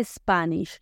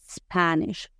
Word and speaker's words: Spanish: lo que vamos a hacer es quitarle esa E que nosotros le metemos en una E imaginaria y pronunciarlo spa. No es special, sino special. No Spanish, sino Spanish. La palabra especial se convierte en inglés en special, special.